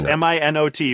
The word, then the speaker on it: Minot: Yes, it's M I N O T, but it's pronounced Minot.